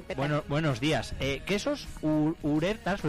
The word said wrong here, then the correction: Ureta: ¿Lo he dicho bien? – Ureta. – Ureta. – Es, joder, mi apellido, ¿verdad? Sí. – Pues le he dicho fatal, entonces. Te he puesto una S, Ureta.